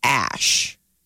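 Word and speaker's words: Æ: I guess the AE character in Scandinavian is pronounced Ash.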